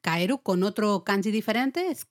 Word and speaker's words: kaeru: Y hemos dicho, claro, rana significa, bueno, se dice en japonés Kaeru. Se escribe con un kanji, con un ideograma concreto, ¿vale? Pero se lee Kaeru.